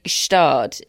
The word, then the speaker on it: Gstaad: Well, anyway, the Swiss one is definitely Gstaad. In fact, I think it's a sort of silent G.